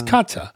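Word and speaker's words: Käthe: And Franz's daughter, Kathy, how do you pronounce that in German? Kata? – Maybe short for Katarina. So yeah, it probably is Kata. – It's Kata. Kata became Adi's wife in 1934.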